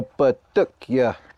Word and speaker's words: Patukya: He's from Hopi, and he also makes tops, which is, how do you say it in Hopi? – In Hopi, we call it a Patukya.